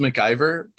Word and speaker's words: McIver: Last name is MacGyver? – McIver. Oh. Close. Close.